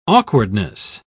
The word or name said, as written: awkwardness